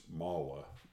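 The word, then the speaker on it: Mahle: If you talk to the Germans, they'll say, you know, like Porsche, it's Mala. But it usually gets pronounced Mali by most people.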